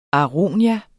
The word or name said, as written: aronia